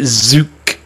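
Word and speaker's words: Tzuke: T-Z-U-K-E. I pronounce it Zuke.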